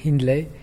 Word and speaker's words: Hindley: Alors, en France, on dit Hindley. Il y a que mes professeurs des écoles qui savaient pas que c'était anglais.